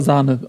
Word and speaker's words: Sané: Laut äh, Peter Neururer musste er aber Sahne ausgesprochen werden, genau, weil er Leroy sich selber, Sahne.